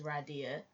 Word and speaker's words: Radia: It is Radia. It's not like Radia or Ridia or anything.